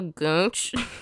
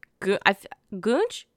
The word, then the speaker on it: goonch: It's also called a goonch. Goonch? Okay, I feel like that's how you say it. It's G-O-O-N-C-H. Tell me that's not goonch.